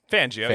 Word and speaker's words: Fangio: Vic Fangio? – Fangio.